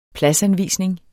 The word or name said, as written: pladsanvisning